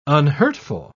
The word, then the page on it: unhurtful: British English